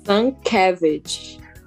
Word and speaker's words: Sienkiewicz: Vou tentar falar Vou tentar pronunciar, vamos ver se eu consigo, se eu falo miseravelmente. O Bill Shankay Não, não consegui. Como que ela disse? – Sankevich.